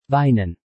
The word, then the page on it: weinen: German